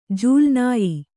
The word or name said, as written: ಜೂಲುನಾಯಿ